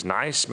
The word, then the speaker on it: NICE: Så spørges der også i samrådsspørgsmålet om udkastet til nye guidelines fra det, som jo forkortes eller udtales NICE, men det er National Institute of Health and Care Excellence.